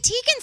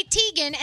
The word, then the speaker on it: Teigen: So Chrissy Teigen says that we have been saying her name wrong. We say Teigen, and it's actually Teigen.